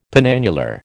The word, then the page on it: penannular: American English